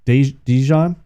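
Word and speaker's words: DeJean: I've been calling him Deshaun, but it's Dijon? Because I believe it's spelled just like Deshaun Jackson's name, so that's what I was saying, Deshaun.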